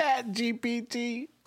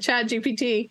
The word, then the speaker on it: ChatGPT: ChatGPT. – Okay, very good. ChatGPT, OpenAI.